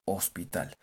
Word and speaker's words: hospital: Solo que, como les dije al principio con la palabra casa, la H suena como una J. Entonces la palabra hospital en inglés se pronuncia hospital. Como si lo dijera Papá Noel, jojo hospital.